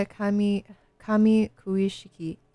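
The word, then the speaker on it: Kamikuishiki: It's called The Story of Kamakushiki Village. Am I saying that right? – Yeah, Kami Kuishiki Village.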